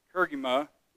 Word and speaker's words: kerygma: This witness, the apostolic kerygma is harmonious.